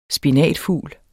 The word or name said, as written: SPINATFUGLENES